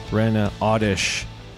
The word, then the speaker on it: Rana Awdish: Okay, we are back with Dr. Rana Awdish.